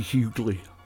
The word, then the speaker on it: Huguley: Hughley H-U-G-U-L-E-Y. My father, the Reverend Billy Nutt, pronounced that Hughley, because it was a Hughley, Alabama.